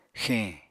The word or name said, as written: xeñ